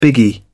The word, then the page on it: biggie: British English